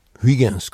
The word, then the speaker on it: Huygens: Comme le néerlandais Christian Huygens, Huygens comme on le prononce en France.